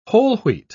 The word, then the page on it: whole-wheat: British English